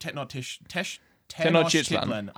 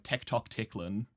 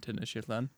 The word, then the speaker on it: Tenochtitlan: Yeah, taking the city of Tenochtitlan. I fucking have it written pronunciation here. I'm still going to mispronounce it. I first read it, and in my head, I was pronouncing it as like Tectoctitlan.